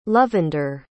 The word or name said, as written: lovender